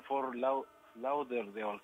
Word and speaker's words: Fort Lauderdale: en un estadio nuevo que está en Fort Lauderdale, creo que se llama.